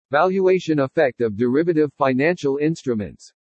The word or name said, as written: VALUATION EFFECT OF DERIVATIVE FINANCIAL INSTRUMENTS